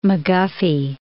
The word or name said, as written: McGuffey